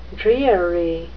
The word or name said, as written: dreary